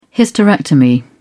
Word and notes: hysterectomy: British English

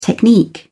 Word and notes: technique: British English

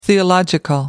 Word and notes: theological: American English

